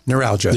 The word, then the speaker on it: neuralgia: Neuralgia.